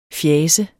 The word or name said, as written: FJASENDE